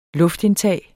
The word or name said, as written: luftindtag